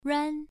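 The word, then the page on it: ruan: tone 1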